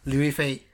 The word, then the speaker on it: Liu Yifei: Liu Yifei, maybe? – Liu Yifei.